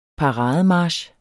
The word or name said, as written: parademarch